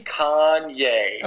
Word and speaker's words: Kanye: How do you say his name? Can you correct me? – Kanye. – Oh, you got it.